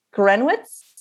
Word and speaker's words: Gryniewicz: And today I'm so excited to have Josh Grunowitz. Did I say that right? – Perfect.